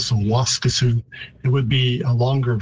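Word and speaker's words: Waskesiu: So the pronunciation isn't too far off. In Plains Cree, I'd say Waskasu. And in Woods Cree, which is the territory where it's located, Waskisu.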